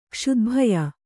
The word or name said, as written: ಕ್ಷುದ್ಭಯ